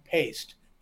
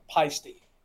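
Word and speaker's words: Paiste: It's, if you talk to the people, they will pronounce their name paste. The family, now, they might call the company pasty or pasty. Some people in America do. If you're in Switzerland, it's paste. If you go to Germany, they have a manufacturing facility in Germany, they call it pasty. Okay? So it depends.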